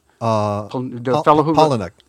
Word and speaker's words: Palahniuk: I'm not sure how to pronounce his name. Chuck Palahniuk? – Palahniuk. Yeah. – Palahniuk.